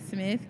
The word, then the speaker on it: Smith: Kashara Smith, K-A-S-H-A-R-A, Smith, S-M-I-T-H.